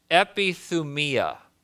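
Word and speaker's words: epithumia (ἐπιθυμία): The Greek word, which is very important here, is epithumia. Can you say epithumia? And that is the right pronunciation.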